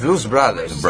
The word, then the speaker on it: Blues Brothers: Pero lo que me hace acordar, ¿se acuerda usted a los hermanos Blue Brothers? Los hermanos Blues, los hermanos Brothers. Blues Brothers. – Brothers. – Sí. – I'm sorry. – Pronuncie bien, porque acá estos somos todos.